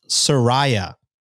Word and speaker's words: Soraya: Second message, it's pronounced Soraya, rolling eyes emoji, which I imagine means that I read the first one. I mispronounced her name.